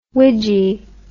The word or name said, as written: widgie